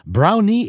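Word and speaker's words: Browne: Oui, alors, il nous a expliqué ce matin que son nom, c'est Omar Brownie. – Brownie. – Exactement. Donc, Brown. – Le E, on le prononce, donc Brownie, et non Brown.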